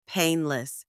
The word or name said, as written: painless